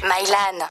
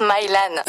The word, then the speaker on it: Mylan: Mylan. Même avec une voix pareille, ça se prononce Mylan.